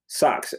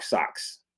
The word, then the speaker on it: SX: Sachs. So SX is actually short for SOX, SOX, right? It's phonetically, the name is Mark Socks, right? – Right.